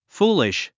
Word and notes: foolish: fúːliʃ; フーリッシュ

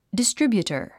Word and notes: distributor: distríbjutər; ディストリビュータァ

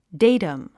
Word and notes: datum: déitəm; デイタム

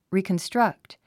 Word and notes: reconstruct: rìːkənstrʌ́kt; リーコンストラクト

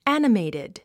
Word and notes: animated: ǽnəmèitid; アニメイテッド

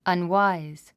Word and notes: unwise: ʌnwáiz; アンワイズ

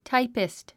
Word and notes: typist: táipist; タイピスト

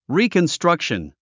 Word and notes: reconstruction: rìːkənstrʌ́kʃən; リィコンストラクション

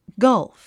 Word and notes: gulf: ɡʌ́lf; ガルフ

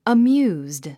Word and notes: amused: əmjúːzd; アミューズド

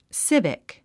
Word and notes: civic: sívik; スィビック

